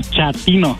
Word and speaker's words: chatino: Chapino. – ¿Chapino? – No, chatino. – Es como chato, ¿verdad? Chatino. – Sí, azunero, como chato, chatino.